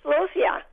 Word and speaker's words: celosia: A lot of this, the celosia, the ones with the big, they've got like big flower heads that are big like blobs almost. Is that how you say it? – Oh, celosia.